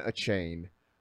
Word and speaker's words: Achane: But as far as we know, it's Devon Achane is how you pronounce it.